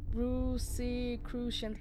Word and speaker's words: Rosicrucians: what is that one? Rosicrucians. Okay, I said it right.